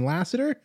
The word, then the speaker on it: Lasater: I'm joined by Aaron Lasater.